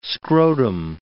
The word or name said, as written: scrotum